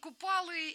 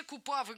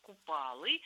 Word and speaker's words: Купалы: Что за день Купалы и правильно ли мы произносим, потому что некоторые вообще Купавы говорят. Ну там что только я не слышала, до седьмого коленя. – Ну, на самом деле, вы произнесли в первый раз правильно. Считается, что надо говорить день Ивана Купалы.